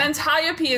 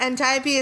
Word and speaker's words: Antiope: Antiope is a name that I pronounce as Antiope for too long. – Do you know how much it took me to remember to not say Antiope when I pronounced it right then? – It's like Hermione.